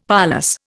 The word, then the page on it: parlous: Indian